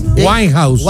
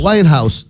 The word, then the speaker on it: Winehouse: Winehouse. – Winehouse, ya.